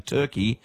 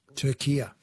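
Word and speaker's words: Türkiye: But in yesterday's Socceroos updates, you kept saying Turkey and not... – Turkia.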